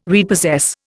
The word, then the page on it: repossess: Indian